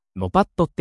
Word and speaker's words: NOPAT: NOPAT はノパットって読みます。